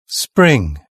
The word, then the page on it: spring: British English